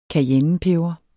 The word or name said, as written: CAYENNEPEBER